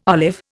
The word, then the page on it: olive: Indian